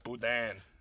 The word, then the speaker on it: boudin: And we're down in New Orleans, and we're conjuring the ghost of Marie Laveau for this one – Boudin. – Okay, Boudin. – I want Boudin balls. – Well, I pronounced it wrong, so she didn't give it to us.